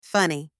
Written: funny

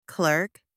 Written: clerk

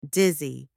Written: dizzy

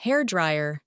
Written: hair dryer

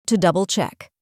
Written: to double-check

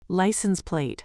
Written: license plate

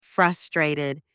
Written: frustrated

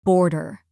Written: border

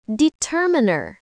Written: determiner

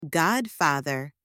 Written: godfather

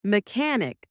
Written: mechanic

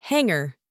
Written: hanger

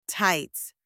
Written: tights

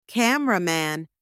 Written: cameraman

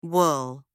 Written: wool

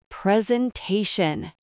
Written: presentation

